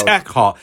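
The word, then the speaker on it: Eckhardt: Surly corrupt detective Lieutenant Max Earhart scoffs at. Thug- it's Eckhart. E- they don't say Earhart, Earhart. This, mate. They say Eckhart.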